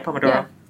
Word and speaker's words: Pomodoro: Yeah, it's the Pomodoro tech. Am I saying that right? – Yeah, Pomodoro.